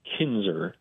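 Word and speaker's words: Knizner: Yeah, you know, they signed Andrew Kinzer. That's how it's pronounced. The first N is silent.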